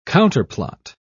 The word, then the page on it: counterplot: British English